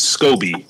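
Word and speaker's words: Scoby: My name is Scobie.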